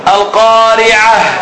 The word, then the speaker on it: القارعة: The one we said at the end of the ayah, you should pronounce it as a ha. Remember in the dars of tafsir, Abdul Majid. – Haqiqa. – لا. Al-qari'ah.